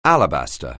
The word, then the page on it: alabaster: English